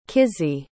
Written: Kizzie

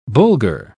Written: bulgur

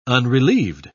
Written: unrelieved

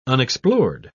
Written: unexplored

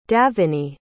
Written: daveney

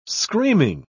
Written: screaming

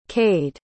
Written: cayde

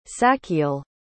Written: sachiel

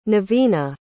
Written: Nevina

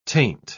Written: taint

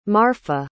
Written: marfa

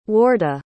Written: warda

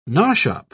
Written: nosh-up

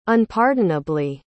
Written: unpardonably

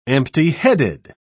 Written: empty-headed

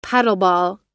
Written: paddleball

